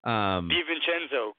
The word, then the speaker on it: DiVincenzo: I'm going to butcher his name, 'cause I always do, but it's Dante DiCenzo. Oh, I didn't do that one too bad. – DiVincenzo, come on, man.